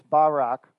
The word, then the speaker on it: Barak: Next word is Barak, spelled Barak, like Barack Obama, but it's Barak is the way it's pronounced.